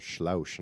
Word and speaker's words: Sluis: Only because I asked the waitress, well, how do I say your town? Because it was like S-L-U-I-S. And I was like, I don't know how to say it in there. And he goes, Schlaus. And I was like, wow, I wasn't expecting that.